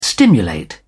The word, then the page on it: stimulate: British English